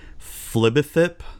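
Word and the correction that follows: Fblthp: Fibblethip finally become a planeswalker.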